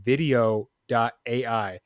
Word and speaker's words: Vidyo.ai: So one of them is called Vidyo.ai, but it's spelled V I D Y O dot AI.